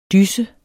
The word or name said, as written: DYSSERNES